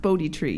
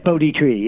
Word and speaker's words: Bodhi: First of all, it's pronounced Bodhi Tree, yes or no? – Yes, Bodhi Tree. Yes. – Bodhi Tree. – Long O. – Yeah, that's what I thought. – So B-O-H-D-I, for those who don't know the word and don't know, of course, the tremendous history with Siddhartha and the birth of Buddhism.